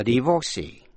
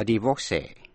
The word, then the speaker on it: divorcee: Và có bốn cách đọc chữ divorcee. Có thể nhấn mạnh vào vần giữa, vor, hay là vần cuối, C. Và âm cuối có thể đọc là E hay A, quý vị nghe.